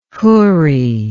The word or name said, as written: Hurri